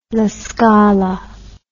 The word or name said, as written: La Scala